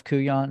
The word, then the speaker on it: Couillon: Is it at Chef Couillon? Is that spelled C-O-U-I-L-L-O-N? – Yeah, now, Couillon.